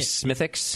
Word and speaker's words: Smithwick's: I was informed by the chat room that the W is generally not pronounced. – Yes. – That's what I thought, too. Smithwick's. – He says Smithwick's, but I am in America.